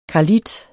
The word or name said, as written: karlit